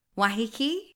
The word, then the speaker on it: Waiheke: Okay, so because I'm a trashy wine drinker, I'd really like to go to Waiheke Island. Is that, have I said that correctly? – Yeah. Waiheke? – Waiheke? – Yeah.